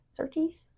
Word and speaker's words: Surtees: John Surtees? – Yeah, Surtees.